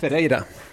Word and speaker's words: Ferreira: Det säger Marcus Barbosa hur uttalar ditt namn rätt? – Ferreira.